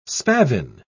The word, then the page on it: spavin: British English